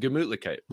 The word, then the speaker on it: Gemütlichkeit: But Gemütlichkeit. It's almost like Gesundheit, like a, you know, sneezing here.